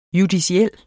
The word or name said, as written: JUDICIELLE